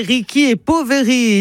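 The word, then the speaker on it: Ricchi e Poveri: Et si on prononce bien à l'italienne, c'est Ricky et Poveri, ici de Romardèche, puisque ce groupe vient de Gênes, les riches et les pauvres.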